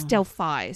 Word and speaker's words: Delphi: It's Delphi. Sorry. – Is it Delphi? – It is Delphi. That's how they say it in America.